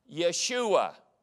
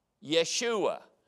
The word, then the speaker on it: Yeshua: They call him Yeshua, the Messiah. Yeshua.